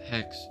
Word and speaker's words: Hex: Vex rhymes with hex, and that's my name.